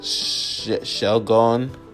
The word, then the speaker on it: Shelgon: So, we got Shelgon.